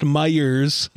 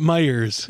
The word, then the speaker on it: Meijer: If you're from the Midwest, you know it's pronounced Meijer's. Like, I was like, it's true, dude. We all call it Meijer's. – Yeah. – Huh. Like Michigan's Michigan Adventures. – No S. There is no S.